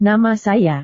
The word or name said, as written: nama saya